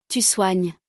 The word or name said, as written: tu soignes